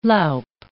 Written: loup

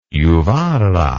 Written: juvarra

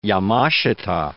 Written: Yamashita

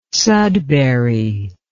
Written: sudbury

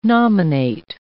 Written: nominate

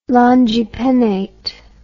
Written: longipennate

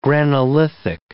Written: granolithic

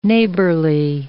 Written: neighborly

